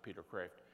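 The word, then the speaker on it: Peter Kreeft: Moreland and Peter Kraft, or sometimes Peter Kraft called, but he pronounces it Peter Kraft.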